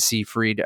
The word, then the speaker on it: Seyfried: Today we are reviewing Sherry Springer Berman's and Robert Pulcini's Things Heard and Seen, starring Amanda Seyfried, a recent Oscar nominee. Amanda Seyfried. I say Fried, okay? – She says Fred, and she literally just posted on Instagram saying, please say my name right.